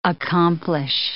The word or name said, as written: accomplish